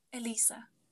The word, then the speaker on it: Elisa: Elisa. But Ellie. – Elisa. Because it looks like Eliza, but it's Elisa.